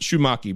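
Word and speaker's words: Shewmake: It's S-H-E-W, Maki, M-A-K, Shoemaker. It looks like Shoemaker, but I'm going to go with Shoemaker, Braden Shoemaker.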